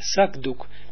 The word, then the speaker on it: zakdoek: Bijvoorbeeld, laten we zeggen, het woord zakdoek. Zakdoek, ja? Ja, zakdoek. Jij schreef het met k, zak. Waarom dat de volgende begint met een klinkende medeklinker, zakdoek.